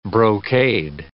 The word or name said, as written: brocade